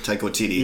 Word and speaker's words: Taika Waititi: I would never pronounce Take it. – Taika Waititi.